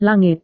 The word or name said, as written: langit